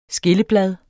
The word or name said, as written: skilleblad